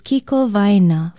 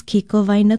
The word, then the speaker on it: kikowaena: Aloha, I'm Leilani Poliahu of Hawaii Public Radio, and our Hawaiian word for today is kikowaena, for center. You can use it to describe such things as a shopping center. Ala Moana Center, for example, is kikowaena kuai o ala moana. Kikowaena is spelled K-I-K-O-W-A-E-N-A.